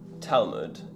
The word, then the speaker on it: Talmud: Talmud, yeah.